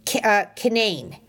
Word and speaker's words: Canaan: and it's spelled Canaan, just like in the Bible, C-A-N-A-A-N, but everybody there pronounces it Canaan. Rhymes with insane, they keep telling me.